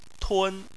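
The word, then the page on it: tun: tone 1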